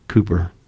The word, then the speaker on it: Cowper: Because I've told you the story before about John Newton and William Cooper, spelt C-O-W-P-E-R, but it's pronounced Cooper in English, right? We would say Cowper.